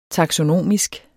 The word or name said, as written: taksonomisk